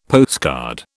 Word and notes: postcard: British English

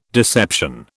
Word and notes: deception: British English

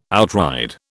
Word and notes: outride: British English

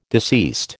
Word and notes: deceased: American English

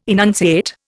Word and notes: enunciate: Indian

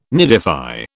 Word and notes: nidify: British English

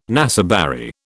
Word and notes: naseberry: British English

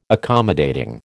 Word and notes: accommodating: American English

